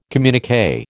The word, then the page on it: communiqué: American English